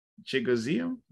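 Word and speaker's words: Chigoziem: Chigozium, is that it? Am I right there? – Yeah, it's like Chigozium.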